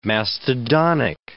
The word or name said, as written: mastodonic